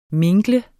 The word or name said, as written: mingle